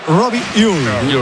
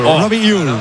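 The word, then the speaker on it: Robbie Ure: Y en concreto sería Robi Uy. Robi Uy. – Qué bien lo pronuncia.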